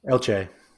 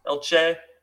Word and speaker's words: Elche: And he's at, how do you pronounce that? Elche? – Elche. – Elche.